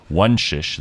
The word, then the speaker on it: Wonsisz: Luno's country manager for France and Italy, Maria Wonsisz, because it's Polish, you see, it's a very difficult surname to pronounce. Wonsisz, that's Maria Wonsisz, talks about how cryptocurrencies are revolutionizing the way we move money.